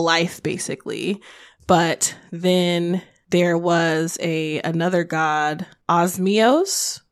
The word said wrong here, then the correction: Osvios: I may be saying that incorrectly. – Osvios. There we go.